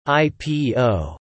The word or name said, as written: IPO